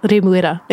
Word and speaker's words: Remuera: And I will never, have lived in Remuera for a couple years, and a lot of people do not know that it's called Remuera, and so they're like, where?